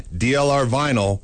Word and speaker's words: DLR vinyl: Remember, for fencing, DLR vinyl rhymes with final.